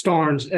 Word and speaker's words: Starnes: Uh, so, is it Eric Jarnes? it's Starnes, S T A R N E S.